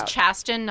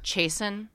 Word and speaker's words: Chasten: You guys, this week, this month, I'm all about Chasten Buttigieg's Twitter. Okay. – Oh, wow. – Like, I've gone on that. – It has been. – I have also. – So his name is Chasten, not Chasen? – No, that was.